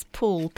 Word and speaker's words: Poole: and it's Poole, P-O-O-L-E, and you always have to spell it to people afterwards.